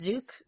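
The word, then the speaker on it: Zuke: I believe that you're talking about Lauren, I think her last name is pronounced Zook. – Lauren Zook.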